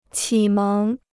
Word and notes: 启蒙: qǐ méng

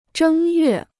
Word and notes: 正月: zhēng yuè

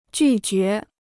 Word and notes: 拒绝: jù jué

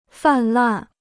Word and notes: 泛滥: fàn làn

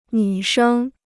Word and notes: 女生: nǚ shēng

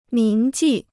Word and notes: 铭记: míng jì

